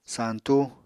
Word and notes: santú: apatani

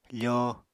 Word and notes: fóo: apatani

